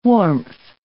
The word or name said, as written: warmth